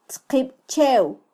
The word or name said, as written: tzqibchew